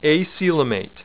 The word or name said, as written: acoelomate